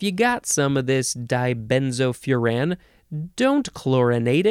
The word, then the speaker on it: dibenzofuran: Dibenzofuran. Dibenzofuran. Dibenzofuran. You can also say dibenzofuran. You can emphasize the last syllable.